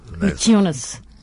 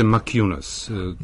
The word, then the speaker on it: Maciunas: Kako bi on trebalo da se izgovori? – Maciunas. – Pa izgovaraju ga, u Americi čini mi se Maciunas.